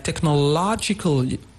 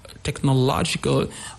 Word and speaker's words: technological: You take the word like technological. Technological. On the syllable low, we have this stress, technological.